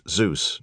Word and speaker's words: ZEVS: The extremely low-frequency transmission, ELF, from the ZEVS transmitter, pronounced Zeus, near Murmansk, was only capable of sending out minimal communications.